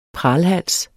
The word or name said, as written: pralhals